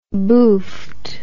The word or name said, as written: boofed